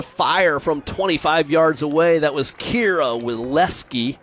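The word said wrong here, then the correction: Wasilewski: I said that wrong. You had it better. How do you say that? – Wazalewski. – Wazalewski, thank you so much.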